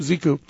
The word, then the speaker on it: Xiku: Ziku, is that how you pronounce X-I-K-U? – Yeah. – Ziku.